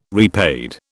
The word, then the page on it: repaid: British English